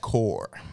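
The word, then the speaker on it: corps: I believe that one's pronounced core.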